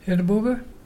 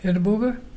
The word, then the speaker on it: irrabuga: Our local Malgana word today is Edinburgh. Edinburgh and dolphin. You spell it IRRABUGA. Can you say Edinburgh?